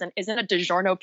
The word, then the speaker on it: DiGiorno: They, like, saw people putting DiGiorno. I don't know if I'm pronouncing that right. – Yeah, you were.